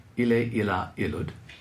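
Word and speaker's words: ille, illa, illud: And this is the classical pronunciation. We'll start with a singular. Ile, ila, ilud.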